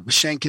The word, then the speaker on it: Schenk: Schenk is pronounced like prison shank.